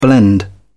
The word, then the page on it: blend: British English